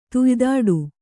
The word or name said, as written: ತುಯ್ದಾಡು